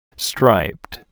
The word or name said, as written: striped